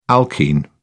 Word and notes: alkene: British English

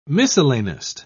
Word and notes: miscellanist: British English

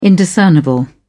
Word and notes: indiscernible: British English

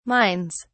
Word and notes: mines: British English

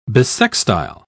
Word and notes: bissextile: British English

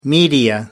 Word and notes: media: American English

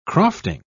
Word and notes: crofting: British English

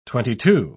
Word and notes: twenty-two: British English

